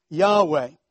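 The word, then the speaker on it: Yahweh (יהוה): But given as a covenant name of God, it is written out in four Hebrew letters, YHWH, that we will pronounce Yahweh.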